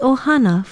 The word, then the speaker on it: ohana: It is spelled Okina, O-H-A-N-A, ohana.